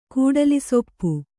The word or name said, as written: ಕೂಡಲಿಸೊಪ್ಪು